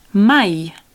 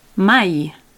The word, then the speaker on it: mai: Okay, if you want to go the other way and say never, that word never is mai. – Mai. – Mai. Okay, it's spelled M-A-I.